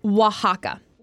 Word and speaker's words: Oaxaca: No, oh, it's Oaxaca.